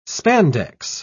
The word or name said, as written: spandex